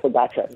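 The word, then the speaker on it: Pogačar: You've just corrected us on our pronunciation there. Pogacar. – Yeah, thank you. – Pogacar, is that how we pronounce Tadej? – Yeah, it's Pogacar.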